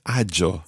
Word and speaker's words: agio: And it's one of those words that we need to be very careful with that G-I, because you almost think, looking at the word, that there are three syllables there, agio, but there's two syllables, sì.